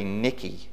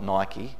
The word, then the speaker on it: Nike: It's actually Nicky, not Nike. Don't tell anybody who's got any Nike sports gear that they should pronounce it another way, they'll think you're silly, but that's the way it should actually be pronounced. The Greek god was called Nicky.